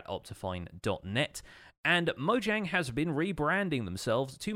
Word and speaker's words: Mojang: It's kind of used informally, and they've explained this in one of their previous Ask Mojang videos, and I've been saying it with the soft J, the kind of Swedish J that's sort of more like a Y sound from English, for a while, but apparently the company name is Mojang.